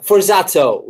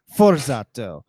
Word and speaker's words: Forzato: Yeah, Forzato with an O. Yeah, Forzato. – Forzato. – That's the American way. But I like it too. – How do you pronounce it? – In Italian, it would be Forzato.